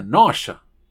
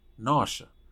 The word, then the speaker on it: nausea: Náusea es como se escribe, como en español. Pero se pronuncia nausea, nausea.